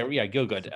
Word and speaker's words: Gielgud: Gielgud, is it? – Yeah, Gielgud.